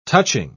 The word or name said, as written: touching